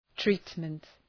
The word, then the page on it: treatment: ‘tri:tmənt